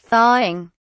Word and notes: thawing: British English

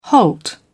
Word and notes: halt: British English

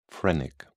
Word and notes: phrenic: British English

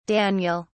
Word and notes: daniel: British English

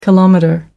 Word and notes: kilometre: American English

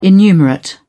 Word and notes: innumerate: British English

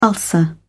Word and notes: ulcer: British English

